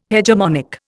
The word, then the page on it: hegemonic: Indian